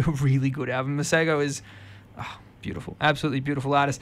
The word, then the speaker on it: Masego: I've seen it as Masego, but that's not right. Masego. I've seen him pronounce it and I'm taking his word.